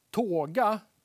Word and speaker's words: toga: Eh, på påstående A då så säger jag att tåga, och det stavas T-O-G-A, tåga eller toga kanske man kan uttala det också, är ett jordbruksredskap från antiken.